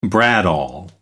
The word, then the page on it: bradawl: American English